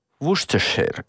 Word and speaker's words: Worcestershire: No että terveisiä sinne ratioon, että se Worcester, hän lausutaan – Worcestershire. Worcestershire.